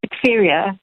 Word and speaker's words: diphtheria: Firstly, am I pronouncing it correctly? And what is diphtheria? – Diphtheria. Yes, you're pronouncing it fine.